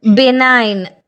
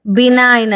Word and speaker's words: benign: ಇನ್ನು G letter silent ಇರೋ ಕೆಲವು ಪದಗಳನ್ನು ಹೇಳ, BENIGN, ಏನಂತ ಸ್ಪೆಲ್ ಉಚ್ಚಾರ ಮಾಡ್ತೀರಾ? BENIGN, ಬೆನೈನ್, ಬಿನೈನ್ ಅಂತೀವಿ, ಹಾಗಂದ್ರೆ ಉಪಕಾರಿ ಅಂತವನು, ದಯಾಳು ಅಂತವನು ಅಂತ, ಬಿನೈನ್, BENIGN. ಇಲ್ಲಿ ಜಿ ಸೈಲೆಂಟ್ ಆಗಿದೆ.